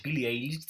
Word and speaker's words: Billie Eilish: Billie Eilish se pronuncia, ¿cierto? – Sí, sí, la Billie Eilish.